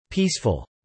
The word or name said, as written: peaceful